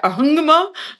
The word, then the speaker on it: Ahangama: Und Fun Fact, also das ist zum Beispiel Ahangama, ist so ein Ort, übrigens auch wird geschrieben Ahangama, aussprechen ungefähr Ahangama, das wird so weggeschluckt.